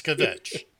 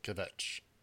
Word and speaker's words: kvetch: It's kvetch. You have to pronounce it correctly, kvetch.